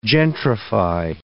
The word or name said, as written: gentrify